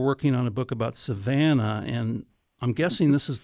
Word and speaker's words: Savannah: Am I pronouncing that right, Savannah? – That's correct, Savannah, Georgia.